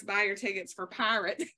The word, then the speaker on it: PYRT: It is called, and it's PYRT, pronounced pirate.